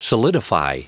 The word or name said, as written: solidify